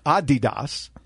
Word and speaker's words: Adidas: Sneaker folks, Adidas, which is actually, having been to Germany every year for 20 years covering IFA, is Adidas, is the correct pronunciation.